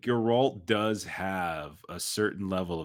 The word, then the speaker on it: Geralt: It's Geralt. It's a hard G.